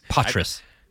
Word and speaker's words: Poitras: Poitras. That's how they pronounce it.